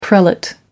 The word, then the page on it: prelate: American English